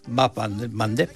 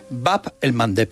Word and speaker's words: Bab el Mandeb: Pues justo en el centro del estrecho que da paso al Mar Rojo, que Bab el Mandeb. – ¿Cómo? – Bab el Mandeb.